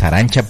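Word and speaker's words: Arantxa: Lo único que pues después hay que estarles explicando por qué, por ejemplo, se escribe con TX. La pronunciación original es Arancha porque es un nombre vasco.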